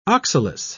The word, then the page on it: oxalis: British English